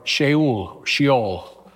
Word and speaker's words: Sheol: One is Gehenna, and the other is Hadas in Greek, or Sheol, Sheol, as we would pronounce it in English.